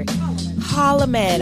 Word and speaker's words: Holloman: Am I saying it all right or? Holloman. Yeah. I'm, I'm like over pronouncing it.